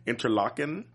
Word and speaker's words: Interlaken: Interlaken.